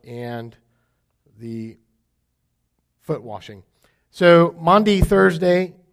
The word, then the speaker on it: Maundy Thursday: Monday Thursday is, or Maundy Thursday, as some would say it properly.